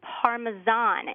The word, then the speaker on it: parmesan: And it's actually parmesan. Instead of ja, it's a Z sound. So parmesan.